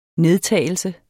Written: nedtagelse

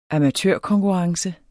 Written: amatørkonkurrence

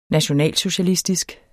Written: nationalsocialistisk

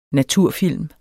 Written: naturfilm

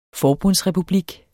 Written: FORBUNDSREPUBLIKKEN

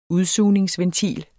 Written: udsugningsventil

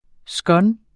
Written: scone

